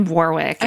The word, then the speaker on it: Warwick: We pronounced his name Warwick in our Warwick. Bios episode. – But the second W is silent, is what we've learned.